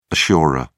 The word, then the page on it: assurer: British English